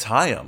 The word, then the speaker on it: Haim: This is our first Haim one of the year, and not Haim, not like Alana Haim, which I learned that's how you say it this year.